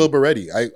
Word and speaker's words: Lil Baretti: Is it Lil Barrett? Lil Baretti? What is his pronunciation of his name? – I think it's Lil Baretti.